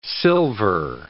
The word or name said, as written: silver